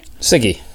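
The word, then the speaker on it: CIGIE: which I think you said is pronounced SIGI. – SIGI, yes.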